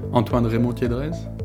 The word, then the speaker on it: Antoine Remond-Tiedrez: Would you mind saying it yourself, just so that I can hear the French pronunciation again? – Sure. Antoine Remond-Tiedrez.